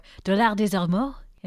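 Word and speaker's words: Dollard-des-Ormeaux: Dollar des Ormeaux, or Dollar des Ormeaux, if we're speaking in French. – Very nice. Good pronunciation.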